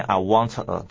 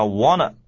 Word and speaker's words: wanna: 再来啊，看到 B 段，我们有位 Miss Mad 啊，气坏了的小姐，她说 I want a refund, I want a, 仍然不是念 I want a, 这个真是麻烦啊。她念说 I wanna, 哎 ,T 夹在 N 跟 A 中间的时候呢 ,T 是不发音的，我们念说 I wanna, I wanna, I wanna。